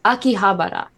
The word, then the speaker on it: Akihabara: Okay, Akihabara. – Akihabara.